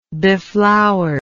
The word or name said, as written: beflowered